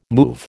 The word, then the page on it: move: American English